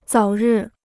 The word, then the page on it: 早日: zǎo rì